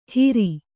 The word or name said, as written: ಹಿರಿ